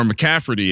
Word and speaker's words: McCaffrey: I, I think he's live for a double touchdown game, 5,400 stacked up with uh, McCaffrey or McCafferty if you if you like that pronunciation better.